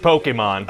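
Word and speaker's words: Pokémon: Well, it's pronounced Pokemon.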